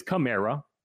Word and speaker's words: Kamara: A lot of people who do pronounce correctly are wrong too. So it's, you know, there's nothing stopping you from being wrong, but it's Kamara.